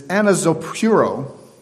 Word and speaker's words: anazopureo: The Greek word Paul uses metaphorically for stir is anazopuro. You spell it. A-N-A-Z-O-P-U-R-E-O.